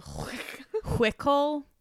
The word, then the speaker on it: Huichol: In Central America, mythological references to horned rabbit creatures can be found in Huichol legends. I think that's how you pronounce that. – You really went heavy on the Huichol. I'm trying. It's spelled H-U-I-C-H-O-L.